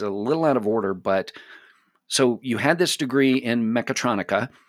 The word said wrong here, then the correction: mechatronics: and did I say that right? Mechatronica? – Mechatronics. – Mechatronics. Okay, I'm sorry. Mechatronics, not mechatronica.